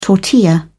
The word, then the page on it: tortilla: British English